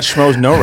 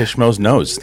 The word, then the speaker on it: Schmoes Know: And you got Schmoes Know right. You didn't say Schmoes Knows.